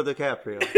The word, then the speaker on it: DiCaprio: It's Leonardo DiCaprio. – Like, that was the Italian. – You sound like eight Tito's and Tito's deep. – That's how you say it.